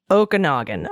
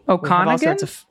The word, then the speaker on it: Okanagan: And as to the other hilarious one, Okanagan. That is one of the most beautiful areas and is pronounced Okanagan. Okay. – Okanagan? – Okanagan.